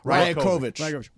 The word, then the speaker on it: Rajakovic: Where is that coach, Darko, how do you say his last name? – Rajakovic.